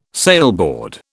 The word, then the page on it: sailboard: British English